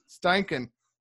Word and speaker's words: Steichen: Steichen, the real deal.